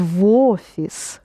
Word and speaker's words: в офис: You pronounce the V together with O, becomes в офис.